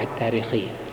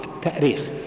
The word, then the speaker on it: التأريخ: طبعا من حيث الأصل الاشتقاقي التأريخ، التأريخ بالهمز لأنها أرخ يؤرخ، الهمز أصلي فيه، أرخ يؤرخ تأريخا.